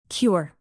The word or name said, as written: cure